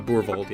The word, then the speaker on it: Boorvaldt: Um, I would have uh, also kind of while Leaf was chatting and kind of after that happened, um, Boorvaldt, saying that right? – Uh, Boorvaldt, yeah. – Boorvaldt.